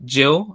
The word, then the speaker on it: Gil: is the incoming of Brian Gill. I don't know if I'm going to be saying his name right. So Brian Gill. Okay, I'll get the Spanish. Gil.